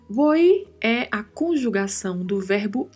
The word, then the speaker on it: voy: É importante ressaltarmos aqui a pronúncia voi, voi. O, a letra V no espanhol ela tem um som muito leve de B de bola.